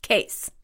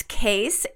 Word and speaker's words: Kees: And I also like, how do you pronounce this member's name? – Case. – And it's K-E-E-S. – Right. It's Case. And they do our social media management. Their name is spelled K-E-E-S.